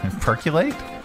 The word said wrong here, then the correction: perchlorate: Perchlorate. – Oh, it's our pronouncify lady here. – Perchlorate. – Okay, perchlorate. – Now it makes sense. Perchlorate. – I'm learning. – Perchlorate. – Yeah. It's perchlorate.